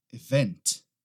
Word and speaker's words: event: Event. Event. Muita gente acaba colocando a ênfase na primeira sílaba, event, event. Não, nesse caso aqui é na segunda, event, event, tá?